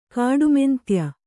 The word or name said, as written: ಕಾಡುಮೆಂತ್ಯ